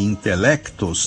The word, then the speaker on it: intelectos: Se o quadradismo dos meus versos vai de encontro aos intelectos, e vai de encontro mesmo, porque é choque, aos intelectos, esse C de intelectos também não é mudo, porque a gente lê, né? A gente pronuncia.